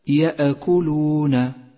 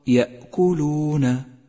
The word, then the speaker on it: يأكلون: أولا، احذر من القفز على حرف الهمزة الساكن في كلمة يأكلون، يأكلون، فإذا قفزت عليها صارت كصفة القلقلة، أي هكذا يأكلون، يأكلون، فهذا خطأ شائع، والصواب يأكلون.